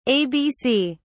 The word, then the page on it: ABC: British English